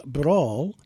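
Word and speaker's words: Bransle: And that's taken from a 1994 album, A Barroom Bransel, which I believe is pronounced Brawl,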